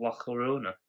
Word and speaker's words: La Llorona: Earliest documentation of La Llorona.